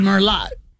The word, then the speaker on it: Merlot: It'd be Merlot. It says Merlot. – I guess so, yeah. – Yeah, remember Merlot is, and that T is silent the T on the end of Merlot.